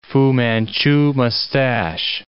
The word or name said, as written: Fu Manchu mustache